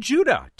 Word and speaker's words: Judah: Ted Judah. Ted Judah in Petaluma, California, won the T-shirt last week. He says, rhymes with Red Buddha.